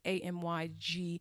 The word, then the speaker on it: amygdala: I looked it up. That's how you pronounce it. It's amygdala. These areas are involved in the self-referential thoughts, your me-centered worry thoughts, your executive control, your emotion, and your fear.